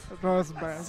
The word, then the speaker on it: Roosevelt: Pero, ¿cómo se dice? – Pero hay un error aquí. – Roosevelt. – Roosevelt. – Sí, señor.